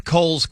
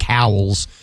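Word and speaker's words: Cowles: This Monday, on the YouTube channel, Seth Cole's Comic, which is spelled SethCoweLesComic. Seth Cole's Comic. It looks like cowls, but it's pronounced Coles.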